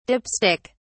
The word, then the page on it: dip stick: British English